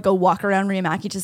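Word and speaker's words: Riihimaki: How do you pronounce it? – Riamaki. You were super close.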